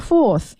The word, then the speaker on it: forth: 这个 forth is spelled as F-O-R-T-H 啊，它是 so forth 是 th 结尾，所以大家在读这个词的时候呢，舌头在 forth 结尾的时候放在上下齿之间。好，接下来一个单词是 campaign 啊， campaign is spelled as C-A-M-P-A-I-G N-S campaign，